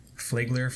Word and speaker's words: Flagler: I don't know how to say it. It's Flagler. Flagler. Doesn't matter.